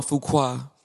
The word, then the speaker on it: Fuqua: Antoine Fuqua.